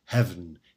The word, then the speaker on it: heaven: Note that heaven here is pronounced with one syllable, who hen itself for ornament doth use, so it scans.